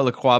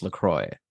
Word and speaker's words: LaCroix: So I used to call it LaCroix because I'm pretentious, but then I saw that even they, on their website, they say, no, it's pronounced LaCroix.